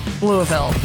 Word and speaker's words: Louisville: But the S is silent. – Louisville.